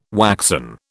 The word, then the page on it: waxen: British English